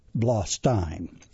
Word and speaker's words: Blostein: Then coming up at Good Nights in January, Lucas Zelnick and Jamie Wolf, Danelle Rawlings, Leah and Andrew Ruddick, and Michael Blostein. Now, I checked it out, and actually it's pronounced both ways. Most of the time it's Steen, but there was a pronunciation of Blostein.